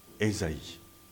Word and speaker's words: Esaïe: En français, dans les traditions juives, catholiques, orthodoxe, on utilise la forme d'Isaïe, Isaïe, et pas Esaïe, Isaïe. Mais chez les protestants, on prononce et on écrit Esaïe.